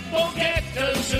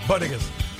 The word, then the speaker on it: bodega: She can't pronounce bodega. Instead, she said boquetas or something like that. – Bodegas.